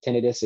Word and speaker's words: tinnitus: The most common association for tinnitus is, and it is tinnitus, so thank you, Dr. Thelman, for getting it right. And often patients will call it tinnitus, so tinnitus is the correct pronunciation for it.